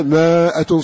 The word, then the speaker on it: مائة: يكون منكم مائة صابرة يغلب مائتين، إيش هذا، هذا إيش هذا، صح هكذا مكتوبة لكن ما تنطق هكذا.